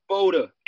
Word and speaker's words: Spoda: Spoda. S-P-O-D-A. People say Spoda, whatever. Like you saying Spoda, you add in a P in front of the S. It's soda with a P, Spoda.